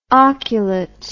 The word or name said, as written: oculate